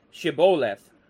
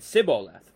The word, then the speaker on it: Shibboleth: And when he said, "No," they said to him, "Then say, Shibboleth." And he said, Shibboleth, for he would not pronounce it right. He said, Sibboleth, for he would not pronounce it right.